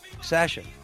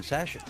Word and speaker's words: Sasha: Sasha. – Exactly, yeah. But it's game night, 10 p.m. – Eastern. – Yeah, that's the proper pronunciation, Sasha.